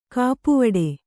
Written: ಕಪ್ಪುವಡೆ

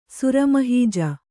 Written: ಸುರಮಹೀಜ

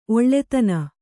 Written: ಒಳ್ತನ

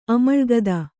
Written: ಅಮಳ್ಗದ

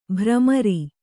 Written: ಭ್ರಮರ

